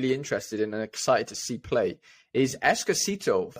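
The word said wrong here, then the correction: Eustáquio: for Porto, sorry. – Estacio. – Centre midfielder. Estacio. There's my Welsh pronunciation being proven wrong.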